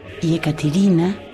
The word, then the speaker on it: Yekaterina: Entonces les cuento que Katyusha es el hipocorístico, o sea, el diminutivo afectuoso del nombre ruso Yekaterina, que se pronuncia así, con la C muy suave, según nos explica Ariel, y cuya traducción al castellano es Catalina.